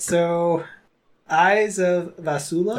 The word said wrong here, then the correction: Valusia: Nope. – That's how you say it. – No, gotta look at all the letters. – Valusia.